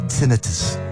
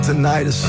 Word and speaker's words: tinnitus: I have a ringing in my ears, and my doctor pronounces it tinnitus, not tinnitus. He knows the ears, nose, and throat, but when the stomach starts to bloat, is it gastritis? All gastritis, we've been saying it wrong all these years. Laymen prefer tinnitus, but the professional says tinnitus.